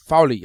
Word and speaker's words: Fowley: K.A.M. Fowler. Fowley. I'm sorry. Fowley.